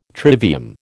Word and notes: trivium: American English

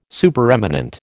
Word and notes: Supereminent: American English

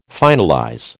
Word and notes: finalize: American English

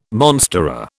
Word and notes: monstera: British English

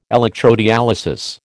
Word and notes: electrodialysis: American English

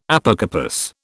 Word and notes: Apocarpous: British English

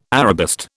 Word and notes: Arabist: British English

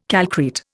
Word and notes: calcrete: Indian

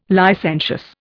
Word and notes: licentious: Indian

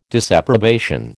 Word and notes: disapprobation: American English

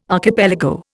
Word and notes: archipelago: Indian

